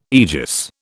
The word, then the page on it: aegis: British English